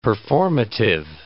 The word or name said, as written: performative